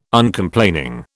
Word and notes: Uncomplaining: British English

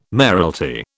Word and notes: mayoralty: British English

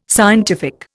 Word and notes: scientific: Indian

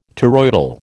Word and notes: toroidal: American English

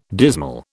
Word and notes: dismal: British English

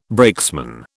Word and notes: brakesman: British English

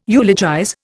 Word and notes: eulogize: Indian

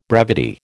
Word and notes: brevity: American English

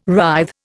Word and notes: writhe: Indian

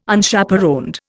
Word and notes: Unchaperoned: Indian

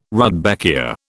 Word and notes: rudbeckia: British English

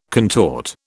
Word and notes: contort: British English

